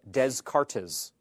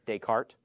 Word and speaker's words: Descartes: René Descartes, a philosopher, his name looks like Descartes. Descartes, but you say it like Descartes.